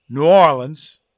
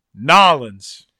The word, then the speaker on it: New Orleans: There's only two correct pronunciations. One, New Orleans. And two, Nolens.